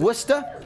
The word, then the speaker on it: Worcester: Worcester. – Love W-U-S-T-A, as we pronounce it.